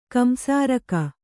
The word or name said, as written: ಕಂಸಾರಕ